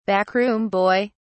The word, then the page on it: backroom boy: British English